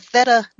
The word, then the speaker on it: theta: Can you explain to us what is a theta brainwave state and why I want to tap into it? – Yes. So I think it's pronounced theta. That's how I've heard okay.